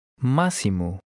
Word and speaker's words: Máximo: In number 11, the X is pronounced like the S in the English word see. Try to read number 11. – Máximo.